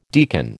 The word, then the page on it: deacon: American English